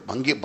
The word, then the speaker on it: भांग: सुनो, वो एक जगह एक भंगड़ भंगड़ कटे हो गए, भंग भंग पी थी उन्होंने, भंग भंग पिए, भंग समझते हो नहीं, भांग भांग भंग नहीं भांग, ये बड़ी प्रोनाउंसिएशन बड़ी जबरदस्त है इसकी, भंगनी भांग।